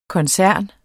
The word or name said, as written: koncern